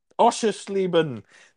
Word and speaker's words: Oschersleben: Oschersleben. – Oschersleben.